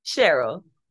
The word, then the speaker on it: Cheryl: Are you pronouncing it Cheryl or Cheryl? – Cheryl. – Cheryl. Okay, great.